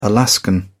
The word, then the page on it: alaskan: British English